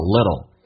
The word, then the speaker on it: little: little t 跟 l 在美式发音发 little，那么发 d 跟 l 的这样的形式，所以你要能听懂，这个对听力会有影响，叫 little， hearing a little gossip，这样调读，再来一遍， we all like hearing a little gossip。